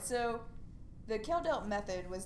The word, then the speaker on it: Kjeldahl: His last name is spelled K-J-E-L-D-A-H-L. That I had to look up that it was pronounced Kjeldelt because...